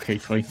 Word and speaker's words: Keighley: Well, this was from the pre-E3 Games Fest show with Jeff Keighley. Keighley. It's a place called Keighley. It's written the same, pronounced Keighley.